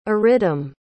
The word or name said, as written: aridam